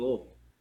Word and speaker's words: Louisville: And yes, that is how it's pronounced. – Louisville. – Louisville. – Louisville. – Louisville. It's very entertaining to try and talk to people that don't understand that. Because they'll say Louisville or Louisville.